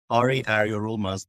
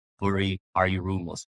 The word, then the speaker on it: Eryorulmaz: So my last name phonetically sounds similar to Eryorulmaz. So it's Eryorulmaz, but most people call it Eryorulmaz.